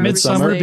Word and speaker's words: Midsommar: Samar? Samar. – I'm just going to say Midsummer. – Midsummer because it's a play on the word Midsummer. – Okay. It's spelled differently. Midsommar, yeah.